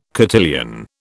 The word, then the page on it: cotillion: British English